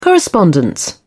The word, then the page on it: correspondence: British English